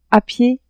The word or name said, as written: a pied